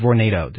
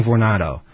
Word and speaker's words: Vornado: apparently they pronounce it Vornado. Did you know that? We've been saying Vornado. I don't know. I don't know. – Rhyme with tornado, I guess.